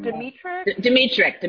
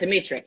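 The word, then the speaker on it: Dmytryk: Is it Edward Dimitrik? – Dimitrik.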